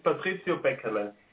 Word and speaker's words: Patrizio Beckerle: Just pronounce your name for us, would you, please? – In German, it's Patrizio Beckerle.